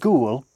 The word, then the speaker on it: ghoul: The word ghoul has a silent H in it.